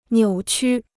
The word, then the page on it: 扭曲: niǔ qū